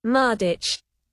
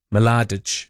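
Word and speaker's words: Mladić: Mardich. – And it does say, you can say Ma, or you can say, like you do with the L, you can go Mladich. Mladich.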